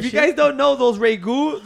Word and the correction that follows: Ragu: those little fucking ¿cómo se dice? – Ragu.